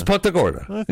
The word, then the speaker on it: Punta Gorda: I think the locals say Punta. – Punta. Yeah. It's Punta Gorda.